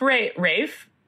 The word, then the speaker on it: Ralph: Pronounced Rafe, not Ralph.